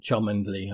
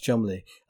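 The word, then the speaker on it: Cholmondeley: And he's saying to somebody, like pretty much as he dies, that it's not Chummondly. I keep telling you. Because it's Chumley, isn't it? It's pronounced Chumley.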